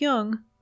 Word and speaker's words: Jung: Just be sure to pronounce Jung with a hard J.